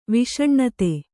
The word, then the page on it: ವಿಷನಿಧಿ: viṣa nidhi